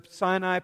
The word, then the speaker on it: Sin: And as they got into the desert called Sin, it's pronounced like the word sin, S-I-N, but it's spelled that way, but in the Hebrew pronounced Sin.